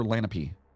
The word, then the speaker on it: Lenape: Is it Lenape or Lenape? – Fucking no. – We're going to say Lenape.